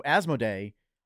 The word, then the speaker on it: Asmodee: So Asmodee, I reported last week, was part of the Embracer split.